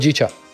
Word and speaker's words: Hojicha: Hojicha, is that how you pronounce it? Hojicha. – Hojicha? – Yeah.